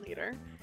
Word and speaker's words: Leeter: Leader? – Captain Leader. – I'm going to spell that L-E-E-T-E-R.